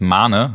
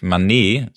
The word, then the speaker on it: Mané: Ja, wie hat dir Filippo Mane, übrigens Mane, habe ich mir sagen lassen, also nicht Mané, sondern Filippo Mane, so will er wohl auch selbst ausgesprochen werden, hat er mal in einem BVB-Interview vor einer Weile auch gesagt, also kurz betont das Mane.